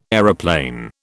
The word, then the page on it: aeroplane: British English